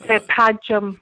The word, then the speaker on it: Padgham: It's Padgham.